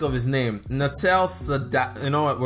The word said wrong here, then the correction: Satya Nadella: Satya Nadella, the CEO of Microsoft, who was one of the people who pushed Microsoft to focus more on cloud computing.